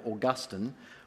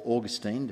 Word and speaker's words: Augustine: Well, you know, after the destruction of Rome by the Goths in 410 AD, a North African pastor by the name of Augustine, or Augustine, depending how you say his name, he wrote a book called The City of God.